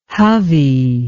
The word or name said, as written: Hovey